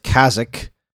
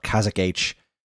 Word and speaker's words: Kazakh: K-A-Z-A-K-H. – Yeah. – I believe the H is silent because I don't think they want me to call it Kazakh H.